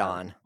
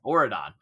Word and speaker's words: Auradon: And in this world, Belle and Beast are married, and they rule a kingdom named Auradon. Am I saying that right? I can't remember. – Auradon. – Auradon. Okay. How they said it in the film.